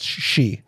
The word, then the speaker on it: sidhe: No, that word, S-I-D-H-E, is pronounced she.